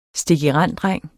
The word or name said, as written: STIKIRENDDRENG